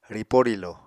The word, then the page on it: ripó-rílo: Apatani